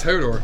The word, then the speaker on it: Hodor: Hordor? – Isn't it Hordor? – No, it's Hodor.